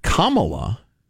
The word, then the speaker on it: Kamala: A friend of mine pointed out it's pronounced Kamala, and you remember because she's a communist.